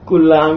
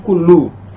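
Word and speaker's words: kullu (كلُّ): Ada yang membacanya kulla amin pakai fathah. Kulla amin wa antum khair. Tapi di sini sih memilihnya kullu. Berarti ini harokat yang ini dibenerin Pak nih, yang ini Pak. Bukan kulla nih.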